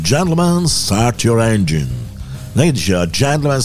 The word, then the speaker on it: engine: ma ragazzi non si dice engine, si dice engine.